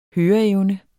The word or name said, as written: høreevne